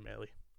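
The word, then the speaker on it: Mahle: I never say his name right. Maley? – Yeah, Tyler Maley.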